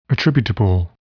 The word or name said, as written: attributable